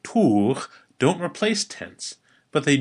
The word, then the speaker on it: tugh: It's tour. Say that with me a couple times. Tour. Tour. That's spelled T-U-G-H.